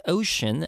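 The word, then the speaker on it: 5tion: The group's name is pronounced Ocean as a play on words with the Korean word for O, 5 being O.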